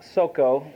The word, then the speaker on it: Soko: Now the Philistines gathered together their armies to battle and were gathered together at Soko, it should be pronounced, which belongeth to Judah.